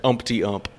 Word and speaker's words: Umpty Ump: Umpty Ump. – Okay. – Umpty like Humpty Hump.